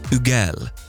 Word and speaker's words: Hugel: Is it like Hugel? Or is it... It is Hugel. Check me out. Wee. Hugel. So the H is silent.